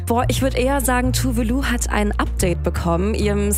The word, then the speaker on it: Tove Lo: Die Sängerin Tove Lo, dachte ich bisher. – Mein Leben ist eine Lüge. So krass. – Nein. Tove Lo, so wird sie nämlich ausgesprochen. Korrektur also, 12 Jahre nach dem Hit.